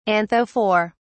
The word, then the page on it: anthophore: British English